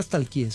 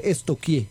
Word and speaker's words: stalkeé: Porque no se dice nunca stalkeé, se dice estoquié.